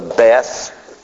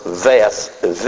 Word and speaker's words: Beth: which is the Beth, B-E-T-H, or Veth, V-E-T-H. So it can be pronounced with a B or a V. Either way, you can say it.